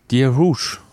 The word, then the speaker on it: Dear Rouge: Wie werden sie richtig ausgesprochen? – Dear Rouge. – Hätte ich auch gesagt.